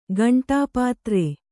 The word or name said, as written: ಕಂಠಪತ್ರ